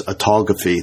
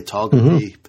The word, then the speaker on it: autophagy: This autophagy, there we go. I said it right. The autophagy pathway so that they could clean up afterwards.